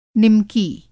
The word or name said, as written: निमकी